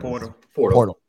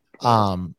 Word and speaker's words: Poeltl: I think, it seems as if the Raptors, as you said earlier today, when they traded for Jakob, how you pronounce his last name? – Jakob Poeltl. – Poeltl.